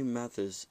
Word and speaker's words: Mathis: Singer Johnny Mathis is 87. Sorry if I mispronounced that last name, by the way. – I think you pronounced it right.